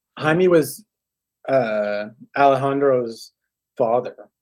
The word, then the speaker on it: Jaime: There it's it's Jaime, but J A I M E, which would be Jaime.